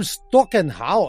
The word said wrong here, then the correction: Stockhausen: uh, uh, Stockhausen, é assim que se pronuncia Stockhausen.